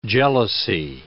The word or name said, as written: jealousy